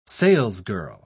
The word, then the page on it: salesgirl: British English